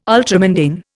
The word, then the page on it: ultramundane: Indian